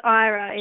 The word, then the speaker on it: Aira: Is that Aira? How do you spell that? – Yeah, so it's Aira. It's A-I-R-A.